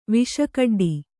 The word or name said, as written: ವಿಷಕಡ್ಡಿ